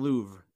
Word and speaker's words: Louvre: Oh, Can't Help Falling in Louvre, like the museum.